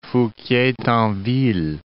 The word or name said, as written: Fouquier-Tinville